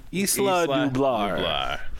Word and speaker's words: Isla Nublar: Isla Nublar.